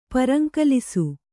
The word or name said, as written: ಪರಂಕಲಿಸು